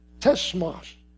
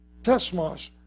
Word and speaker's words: tsemach: The branch, that's from a Greek or a Hebrew word, it's spelled T-S-E-M-A-C-H, tesmash, tesmash, which means branch, which is the sprouting one.